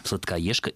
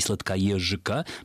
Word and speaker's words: Сладкоежка: То есть получается, что «Ж» в позиции перед «Каф» будет всегда оглушаться, поэтому произносить, конечно, мы будем «Сладкоежка» и «Сладкоежика» произнести просто невозможно русскому человеку.